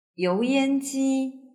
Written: youyanji

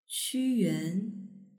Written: quyuan

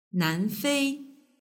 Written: nanfei

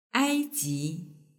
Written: aiji

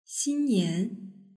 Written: xinnian

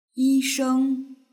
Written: yisheng

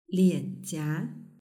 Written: lianjia

